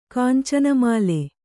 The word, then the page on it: ಕುಂಚನ್ಮೂಲ: kuncanmūla